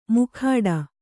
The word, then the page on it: ಮುಖಾಡ: Kannada